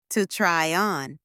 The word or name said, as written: to try on